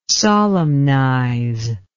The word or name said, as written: solemnize